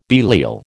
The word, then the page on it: Belial: American English